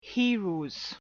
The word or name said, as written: heroes